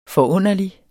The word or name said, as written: forunderlig